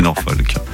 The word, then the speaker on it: Norfolk: Mais vous savez même pas le prononcer. Mais oui, mais bien sûr, Sébastien, bravo à vous. C'est Norfolk.